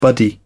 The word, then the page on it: buddy: British English